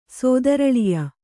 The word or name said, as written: ಸೋದರಳಿಯ